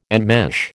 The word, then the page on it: enmesh: American English